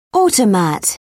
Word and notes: automat: British English

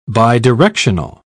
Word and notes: bidirectional: British English

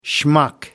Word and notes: schmuck: American English

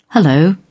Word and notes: hullo: British English

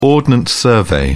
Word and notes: ordnance survey: British English

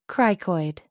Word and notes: cricoid: American English

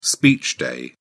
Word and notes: speech day: British English

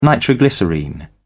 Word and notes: nitroglycerine: British English

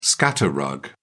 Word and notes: scatter rug: British English

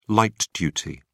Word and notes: light-duty: British English

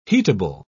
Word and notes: heatable: British English

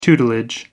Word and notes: tutelage: American English

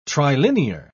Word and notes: trilinear: British English

